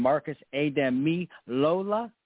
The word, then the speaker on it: Marcus Ademilola: He also spent some time at Bucknell, but it's none other than Marcus Ademilola.